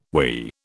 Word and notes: we: British English

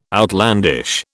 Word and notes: outlandish: British English

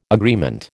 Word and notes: agreement: American English

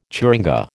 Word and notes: churinga: American English

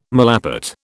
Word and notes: malapert: British English

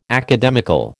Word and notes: academical: American English

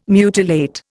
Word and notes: mutilate: Indian

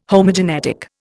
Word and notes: Homogenetic: Indian

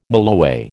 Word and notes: mulloway: American English

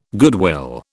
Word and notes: goodwill: British English

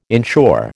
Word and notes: inshore: American English

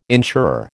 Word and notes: insurer: American English

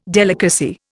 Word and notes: delicacy: Indian

